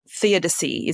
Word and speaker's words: theodicy: Yeah, you refer to it as theodicy.